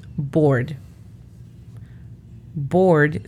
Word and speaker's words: board: La pronunciación fonética de board es B-O-R-D. Acá sí calcamos lo más que se pueda la letra R. Board.